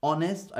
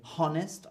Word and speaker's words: honest: Honest, I don't say honest, I say honest.